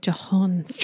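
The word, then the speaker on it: J'onn J'onzz: John Jones is spelled J'ONNJ'ONZZ. – So it's like Jahons.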